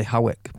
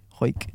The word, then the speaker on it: Hawick: spelled H-A-W-I-C-K. So I'd say Howick, but she's said pronounced H-O-Y-K, Huyck.